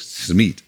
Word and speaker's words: Smith: Und da hat der Kollege Benny Götsch, der den Hut auf hatte am vergangenen Wochenende bei dieser Sendung, hat nochmal nachgehört, wie er sich selbst ausspricht und er spricht sich tatsächlich Smith aus.